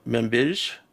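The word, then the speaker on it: Manbij: Membij, M-A-M-B-I-J, in the fall of 2016.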